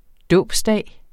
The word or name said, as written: dåbsdag